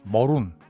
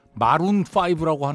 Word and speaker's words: Maroon: 뭐 늘 얘기 드립니다만 이 팀 이름은 MAROON 네, 머룬 이렇게 발음됩니다. 근데 우리가 영어 발음에 그렇게 민감하게 늘 얘기하면서 왜 이건 계속 마룬5라고 하는지 저는 네, 영어는 발음 기호가 있습니다. A로 써있다고 그래서 그게 R로 발음되지 않거든요. 예, 머룬입니다.